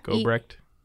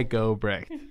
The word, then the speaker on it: Gobrecht: Gobrecht. – Yes. – Eli Gobrecht.